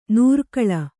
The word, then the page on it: ನೂರ್ಕಳ: nūrkaḷa